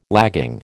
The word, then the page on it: lagging: American English